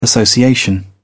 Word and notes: association: British English

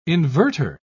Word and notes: inverter: British English

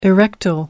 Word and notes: erectile: American English

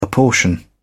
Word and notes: apportion: British English

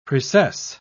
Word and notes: precess: British English

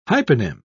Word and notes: hyponym: British English

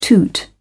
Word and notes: toot: British English

